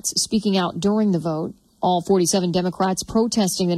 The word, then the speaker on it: Vought: Yeah, in reality, it wasn't about, slowing down the vote for Russell. Vote. There we go. We got the correct pronunciation of his name there.